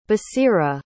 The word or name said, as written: basira